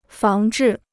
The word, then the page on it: 防治: fáng zhì